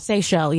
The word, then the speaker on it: Seychelles: Have you ever heard of the, I believe it's pronounced, is it pronounced Seychelles? – Seychelles, yeah. – The Seychelles? – Yeah. – The islands? – S-E-Y-C-H-E-L-L-E.